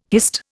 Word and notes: hist: Indian

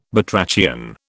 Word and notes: batrachian: British English

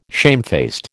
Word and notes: shamefaced: American English

